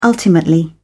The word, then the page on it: ultimately: British English